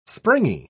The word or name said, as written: springy